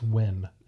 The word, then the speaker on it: Nguyen: Nguyen. – It's one of those that are like, it's Wen.